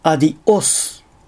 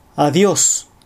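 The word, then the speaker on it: Adiós: アクセントに気を取られるあまり、アディオス、エスタシオン、シトアード、マジェストオソのように、単語の途中に正門閉鎖を入れてしまう。こういう発音をするとスペイン語としては大変聞き苦しい感じになります。ここは正しく Adios Estación Situado Majestuoso と発音したいところです。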